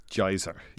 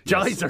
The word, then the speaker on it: Jizer: I'm not sure how to pronounce it, though. J-I-Z-E-R? – Geyser. – Geyser, yes. Okay.